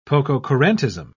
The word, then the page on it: pococurantism: British English